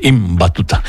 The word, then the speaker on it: Ibn Battuta: Hay que pronunciar bien, Ibn Battuta.